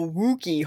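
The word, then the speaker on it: Wookey Hole: I don't know how to say it. Wookiee. Hole caves. – Sounds right to me.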